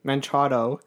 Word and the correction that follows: Machado: How's it pronounced? – Machado. – Machado. – There's no N in there. There's no I in there. It's not Minchado.